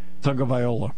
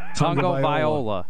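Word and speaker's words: Tagovailoa: But how do you say it? – Tagliola. – What? – Tagliola. – Tagliola.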